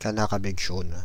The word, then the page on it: canard à bec jaune: France (Île-de-France); /ka.na.ʁ‿a bək ʒon/